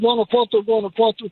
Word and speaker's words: Guanajuato: It's between there and Guanajuato, close to the airport.